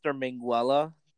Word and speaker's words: Minguela: My username for Twitter is at Mr. Minguela 3.